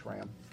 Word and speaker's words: SRAM: On page 45 of the blue brief, you say that the SRAM declaration, am I saying that right, SRAM? – Yes, sir.